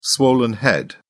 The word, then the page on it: swollen head: British English